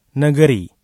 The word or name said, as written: negeri